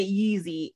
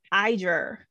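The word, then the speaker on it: Yizhi: I was pronouncing it Yeezy. I was like, he was Kanye West up in this piece. No, it's Iger. Close to that. So the Y is an I sound.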